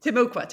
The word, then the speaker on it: Timucua: Um, so in the first few paragraphs of the book, you introduce the story of uh, I'm sorry, Timucua Eve. Am I pronouncing that correctly? – Timucua, Timucua, yeah.